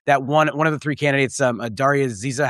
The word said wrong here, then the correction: Daria Liza: how do you say her name? You said it, Adam, and I don't want to say it wrong. – It is Daria Liza.